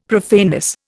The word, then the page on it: profaneness: Indian